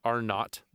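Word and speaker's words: R0: To estimate how easily a virus spreads, scientists calculate its, I'm using air quotes, basic reproduction number, or RO, pronounced R not. Apparently, zero is pronounced not.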